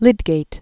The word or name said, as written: Lydgate